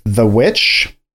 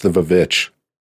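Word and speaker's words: The VVitch: This is a director that I personally really love, who debuted with The Witch. – I think it's pronounced the Vavitch. – No, it's not.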